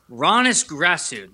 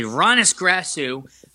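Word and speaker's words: Hroniss Grasu: I think it's pronounced Ronis Grasu. The H is silent. It's weird. It's spelled H-R-O-N-I-S-S. So I'm going to say Ronis Grasu, and they signed him a former.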